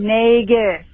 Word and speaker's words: Nagus: It's Grand Nagus. Fucking say it right. Love you guys. Bye. – So I tried to correct it, and Lieutenant Thomas from inside the Patreon said it's a hard G like Ferengi, so hopefully that will drill it in to my head.